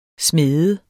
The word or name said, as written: smæde